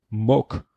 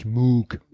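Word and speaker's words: Mogg: Jacob Rees heißt Mock, nicht Moog. Ich dachte, der wird mit Doppel O geschrieben, wie der ja. Synthesizer, er wird aber mit Doppel G geschrieben. Okay. Also heißt er Mock.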